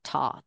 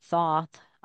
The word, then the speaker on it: Thoth: Now, back in the Egyptian times, there was a entity called Thoth or Toth. I've heard it pronounced both ways. It's spelled Thoth. I pronounce it Thoth. I have heard people pronounce it Toth.